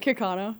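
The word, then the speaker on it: Kaukauna: So Kakuna, am I pronouncing that right? – Kakana. – Kakana, Wisconsin. Okay, gotcha.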